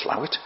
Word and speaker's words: Slaithwaite: Parts of the canal were filled in, and in the holy town of Slaithwaite, which is actually pronounced Slawit, but the holy town there, the whole of the canal was filled in and a big car park built.